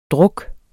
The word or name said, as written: druk